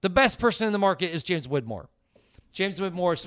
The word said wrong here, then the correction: Wedmore: Wedmore. That's how Wedmore. – It's spelled. – Wed, W-E-D-M-O-R-E.